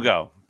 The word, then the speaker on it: Verdugo: Verdejo. How do you pronounce his Verdugo. – Name? – Verdugo.